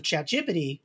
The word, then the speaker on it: ChatGPT: And but if you're going to build a, like, you know, your quick and dirty AI off of ChatGPT,